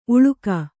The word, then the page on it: ಊಳಿಗಿ: kannada